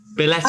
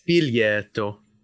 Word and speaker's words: biglietto: Billetto. – I don't know if you pronounced that correctly, but I did. You can check me on that. – Say it again. – biglietto.